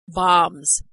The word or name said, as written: bombs